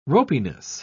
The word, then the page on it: ropiness: British English